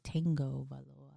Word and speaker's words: Tagovailoa: Yeah, but it's like it's like a silent N in there because it's like Tagovailoa.